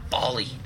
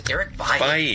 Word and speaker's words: Bailly: I hate that you all call him Bali. It's Eric Bailly.